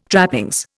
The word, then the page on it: trappings: Indian